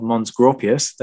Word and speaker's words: Mons Graupius: The Romans in Scotland, the Battle of Mons Graupius. There we go. I've learned to pronounce it properly now.